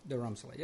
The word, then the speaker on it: Dharamsala: Dharamsala, yeah.